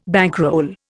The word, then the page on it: bankroll: Indian